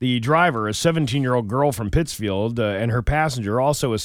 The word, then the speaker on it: Pittsfield: She was released on $40 bail and is scheduled to appear in Pittsfield District Court on Tuesday. – I believe it's pronounced Pittsfield.